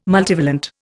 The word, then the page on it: multivalent: Indian